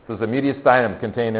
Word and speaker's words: mediastinum: I think most people mispronounce it, so I'm going to say it. It's called mediastinum. Mediastinum. Go ahead and say it like that. Mediastinum. Yeah. You might hear mediastinum and all kinds of really cool things, but mediastinum is the way we say that.